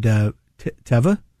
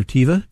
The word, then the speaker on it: Teva: Mr. Buffett had Teva Pharmaceuticals, or Teva, depending on how you pronounce it.